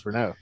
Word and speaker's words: Renault: But uh, well, the good thing is actually knows how to pronounce Renault. – Renault. – yes, not, not a Renault.